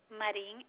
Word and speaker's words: Marin: My first name is Maria, M-A-R-I-A. – That was M-A-R-I-A. Will you spell that last name? – Marin, M-A-R-I-N.